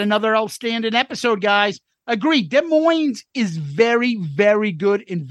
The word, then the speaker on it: Des Moines: Love both listening to Soundboard at Des Moines, the S is silent in 77, and your coverage of it.